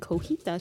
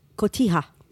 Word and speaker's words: cotija: cotija cheese. – You mean cotija. – Oh, I always say that wrong.